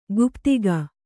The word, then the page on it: ಗುಪ್ತಕ: Kannada